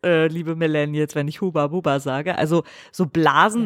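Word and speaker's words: Hubba Bubba: Heißt das eigentlich wirklich Huba Buba oder ist es einfach falsch ausgesprochen? So ein bisschen so wie Mirakel. – Naja, das ist amerikanisch. Wahrscheinlich heißt es Haba also Baba. Es Haba Baba, ja.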